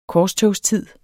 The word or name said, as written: korstogstid